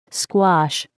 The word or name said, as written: squash